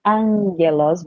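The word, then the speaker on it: angelos (ἄγγελος): Então, angelos, que a gente aprendeu como é que se pronuncia aqui quando tem dois gamas juntos, angelos vira um N, né? Então fica angelos o.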